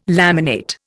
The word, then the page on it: laminate: Indian